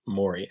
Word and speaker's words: Morey: Someone from like the Sixers PR Harris Blitzer PR was like, by the way, it's pronounced Morey.